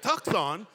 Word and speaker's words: Tucson: Yeah, that's how you say it. Anybody from Tucson know how to say it. Tucson.